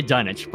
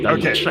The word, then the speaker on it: Dunwich: Is it Dunwich or Dunwich? – I think the British say Dunwich, but here in the U.S., we say it the right way. It's Dunwich.